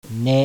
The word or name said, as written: NE